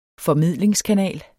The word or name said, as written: formidlingskanal